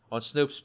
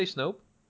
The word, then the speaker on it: Snoke: So, yeah, we're going to call him Sweeper Snoke. McSweeper. Let's go back to Snoke. So now we're back on Snoke's massive...